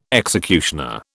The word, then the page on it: executioner: British English